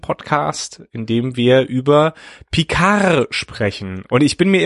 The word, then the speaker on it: Picard: Da müssen wir jetzt überhaupt erstmal anfangen, wie wir überhaupt Picard aussprechen. Das gute deutsche Picard Pika.